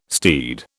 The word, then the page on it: steed: British English